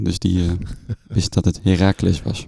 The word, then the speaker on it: Heracles: Heracles, ja.